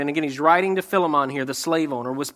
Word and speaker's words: Philemon: which is the right way to say Philemon, but he writes to Philemon regarding his escaped slave Onesimus.